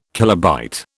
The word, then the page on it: kilobyte: British English